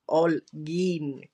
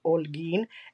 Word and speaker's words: Holguin: and I'm like it's Olgin con H H but you can't pronounce H it's Olgin and I love it